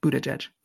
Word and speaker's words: Buttigieg: It's boot, thank you. Spelled butt, but yes. Phonetically, it looks like Buttigieg, which is not correct. Buttigieg.